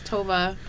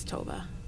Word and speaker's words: Tove: T-O-V-E. Yeah, it's Tova. My boss is Norwegian, and that's her name. – That's dope. How you pronounce it, is Tova.